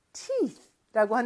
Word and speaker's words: teeth: tooth하고 teeth라는 거를 잘 기억해 주셔야 되는데 그 마지막 th 발음이 조금 어려울 수 있을 것 같아요. 이렇게 바람 소리거든요. 윗니와 아랫니 사이에 혀를 껴놓고 그리고 쓰라고 하는 것처럼 바람을 내뿜는 소리예요. 이렇게 teeth, tooth. 네, 제가 좀 과장되게 했지만 그렇게 발음해 주셔야 돼요.